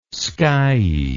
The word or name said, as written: skiey